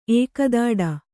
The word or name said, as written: ಏಕದಾಡ